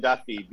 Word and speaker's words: Duthie: Try being Duthie, because obviously the people who watch TSN might know me, but most people say Duffy all the time, like D-U-F-F-Y.